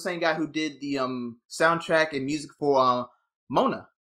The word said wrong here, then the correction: Moana: I think that's how you pronounce it. Yes, because that's one of my favorite. – Moana. – Moana. I keep forgetting how that's pronounced because the spelling and the pronunciation are so different.